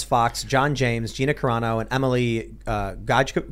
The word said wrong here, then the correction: Gojkovic: Gojkovic. – Gojkovic. There you go. Wow, I totally butchered that name.